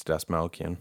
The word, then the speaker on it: Dastmalchian: I can't see it, but I think it's Dastmalchian. – That's the one.